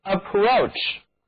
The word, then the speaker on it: approach: 接近是 approach， approach 含有词根 PROACH，这个词根的意思就是趋近接近，而 AP 表示向着某个方向靠着，字母 A 读作倒写的 A，那么 OA 组合呢读作 O， CH 组合在一起的读音是 CH，嘴唇需要稍微的向外凸起一点 CH，这个词就读作 approach， approach 就是接近靠近，它既是动词还可以充当名词。